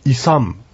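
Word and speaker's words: isam: はい、ここでちょっと発音をですね注意していただきたいのは一番最後ですね、イサムというやつですね。イサムと書いてますけども、これは M の発音ですね。口を閉じてイサムイサムというふうに運のようにも聞こえますけども、本当はイサムという感じですね。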